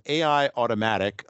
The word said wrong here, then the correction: Aitomatic: Well, Aitomatic